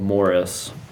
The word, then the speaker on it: Maurice: And so one of the ones that he told me about was, it's called Morris. It's spelled like Maurice.